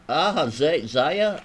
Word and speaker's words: Ahaziah: Ahaziah, I may be mispronouncing that.